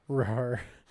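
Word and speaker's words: Rahr: We're just assuming that's how you pronounce the first word. – Yeah. It's R-A-H-R. – Rar.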